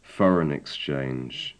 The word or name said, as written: foreign exchange